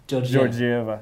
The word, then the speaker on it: Georgieva: Georgieva.